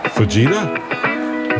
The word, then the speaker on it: Fujita: It's almost like fajita, but with a J. – Fajita has a J as well. – It does. – But it's pronounced Fujita, I think. He doesn't pronounce it fajita.